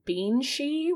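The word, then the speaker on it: bean sí: So the word banshee comes from the Irish term beanshee, which is, pronounced just like that. It's like banshee, which translates to woman of the fairy mounds or woman of the mounds.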